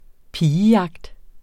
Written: pigejagt

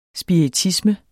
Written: SPIRITISME

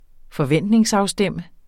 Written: forventningsafstemme

